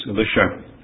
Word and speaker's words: Elisha: There were two great prophets at this time of Israel, Elijah, and therefore some will say Elisha to distinguish from Elijah, some say Elisha. Perhaps more close to the original name is Elisha. So that's the way I'll pronounce it as we read here this morning.